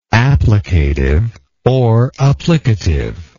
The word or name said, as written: applicatively